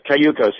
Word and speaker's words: Cayucos: Cayucos, yeah.